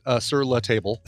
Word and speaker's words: Sur La Table: But we'd wanted to take some classes at the local Sur La Table, or as most folks call it, Sur La Table. – What is this place? – As it should be, as it should be pronounced.